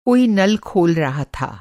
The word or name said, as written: खोलना